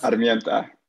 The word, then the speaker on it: Armienta: Armienta. – Armienta. I got to get the rolling of the R.